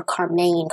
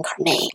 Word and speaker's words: Karmein: This often left their oldest daughter, 13-year-old Carmen, I think I'm saying that right, K-A-R-M-E-I-N, or Carmaine, Carmaine, like low main.